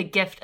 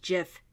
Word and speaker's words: GIF: It says the creator of GIF says it's pronounced GIF. He is wrong. So because the G stands for graphic and it's graphic, right. Not graphic. – Right. – So that's why it's supposed to be GIF.